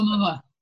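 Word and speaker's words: Oumuamua (ʻOumuamua): For example, when that asteroid came through the solar system, that I can't pronounce the name, how do you pronounce Oumuamua.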